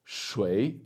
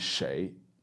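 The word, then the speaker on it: shei (谁): but she says shui instead of shei. So when she's saying who in Chinese, the most common pronunciation by far is shei, right? But also it's fine, absolutely fine to say shui.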